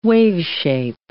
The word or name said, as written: waveshape